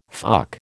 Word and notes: fuck: American English

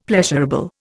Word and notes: pleasurable: Indian

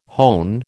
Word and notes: hone: American English

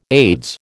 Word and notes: Aids: American English

